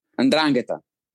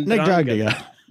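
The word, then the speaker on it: 'Ndrangheta: How do you say that? – Andrangheta. – And that, Andrangheta. Ndrangheta, then yes.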